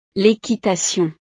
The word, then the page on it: l'equitation: frances